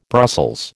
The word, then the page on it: Brussels: American English